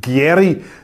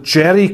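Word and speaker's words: Gieri: Der Mann heiße nicht Gerry, sondern Jerry Gavelti.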